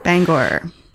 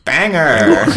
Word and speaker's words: Bangor: Oh, and also, as a born and bred Mainer, I will say that I do not co-sign pronunciation of the town Bangor. – Bangor. – Banger. – Which is what everyone thinks they say in Maine, but they don't.